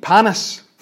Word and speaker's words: panis: Panis.